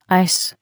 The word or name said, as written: ice